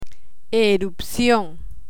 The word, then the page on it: erupcion: Spanish